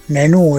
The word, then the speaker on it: Nenú: Oí el comentario de la maestra Nenú. Nenú es con acento en la U, de San Jacinto.